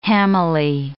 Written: hammily

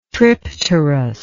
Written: tripterous

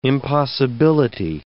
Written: impossibility

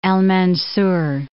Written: Mansar, al-

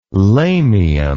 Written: lamium

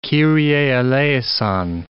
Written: kyrie eleison

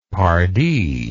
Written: pardy

